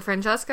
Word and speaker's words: Francesca: There's multiple points where the woman in the book, Francesca, is it Francesca? Or, that's it, right? That's how you pronounce that name.